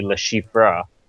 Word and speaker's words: Le Chiffre: The proper way to pronounce it would probably be Le Chiffre.